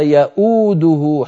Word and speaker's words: يؤوده: أيضا مر معنا في آية الكرسي ولا يؤوده حفظهما سبحانه وتعالى، انظروا إليها من كان منكم يفتح مصحفا، المفروض أن تكتب واوان، الواو الأولى كرسي للهمزة والواو الثانية هي الواو المنطوقة ياو، أنا لما أقول ياو أنطق همزة مضمومة وبعدها واو ولا